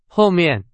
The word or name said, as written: 后面